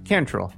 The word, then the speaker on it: Cantrell: Did I say that right? – Cantrell. – Cantrell. – Close enough, yeah.